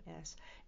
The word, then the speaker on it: Reims: And it's not spelled that way, by the way. It's Reims. Everything is pronounced differently, if you know the French language, than what it looks like, than what it appears to be.